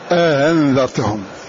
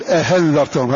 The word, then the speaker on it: أأنذرتهم: فمثال تسهيلهم للأولى إن كانت مفتوحة أأنذرتهم، فيقرؤون أأنذرتهم، فالثانية هذه تنطق بينها وبين ما منه حركتها، وبعض الناس يقلبها هاء هذا خطأ فيقول أأنذرتهم هذا خطأ، فمن يقرأ هكذا هذا خطأ، خطأ في القراءة خطأ في الرواية، ولكن التسهيل يؤخذ بالتلقي والمشافهة.